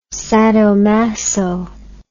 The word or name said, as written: sadomaso